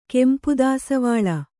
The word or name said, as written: ಕೆಂಪುದಾಸವಾಳ